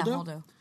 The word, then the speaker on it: Holdo: Holdo is. Is that how you say it? – Holdo? Yeah, Holdo. I keep yeah.